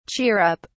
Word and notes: cheer up: British English

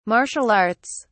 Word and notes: martial arts: British English

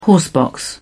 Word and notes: horsebox: British English

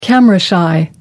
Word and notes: camera shy: American English

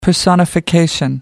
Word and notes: personification: British English

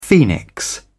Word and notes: phoenix: British English

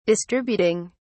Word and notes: distributing: British English

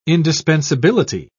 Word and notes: indispensability: British English

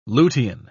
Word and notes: lutein: British English

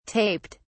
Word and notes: taped: British English

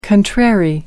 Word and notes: contrary: American English

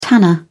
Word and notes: tanner: British English

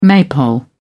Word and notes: maypole: British English

